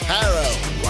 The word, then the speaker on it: Harrell: Now, here is your host, the fantastic Clydesdale, Jeff Harrell, rhymes with barrel.